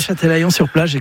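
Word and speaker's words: Châtelaillon-Plage: à Châtelaillon-sur-Plage, c'est comme ça qu'on prononce. – Oui, Châtelaillon-sur-Plage,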